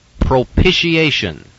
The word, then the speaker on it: propitiation: Once again, may I emphasize the pronunciation, propitiation.